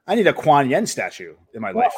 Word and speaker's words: Kuan Yin: It's spelled differently, but it's Kuan Yin.